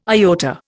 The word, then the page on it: iota: Indian